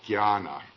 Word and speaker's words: Jnana: J-N-A-N-A, and it's pronounced Jnana, as though J-N were G-Y, Jnana. They put an enya, you know that Spanish enya, over the first N there, Jnana. The religious one is bhakti, B-H-A-K-T-I. The action one is karma, K-A-R-M-A.